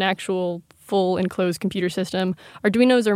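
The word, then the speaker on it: Arduino: Arduinos. – Oh, yeah. Arduinos.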